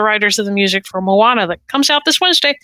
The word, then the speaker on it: Moana: M-O-M-O-A-N-A. Moana.